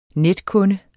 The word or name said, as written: netkunde